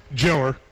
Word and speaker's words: jailer: Now, for jailer in Bell County, or as we pronounce it, You're from Bell County. Jiller. Yeah.